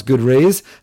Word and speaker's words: GoodRaz: G O O D R A Z dot com, but pronounce good rays.